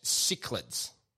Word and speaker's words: cichlid: And they're, sorry, it was cichlids. – Cichlid. Yeah. I know it's spelled with a C, but it's cichlid.